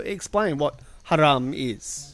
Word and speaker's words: haram: They've been saying it very bogan. – What are some of the pronunciations you get? – We got harem. That was the funniest one. – Like a harem, a multi-wived. – Yeah. Changes the whole meaning of the term.